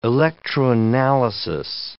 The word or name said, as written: electroanalysis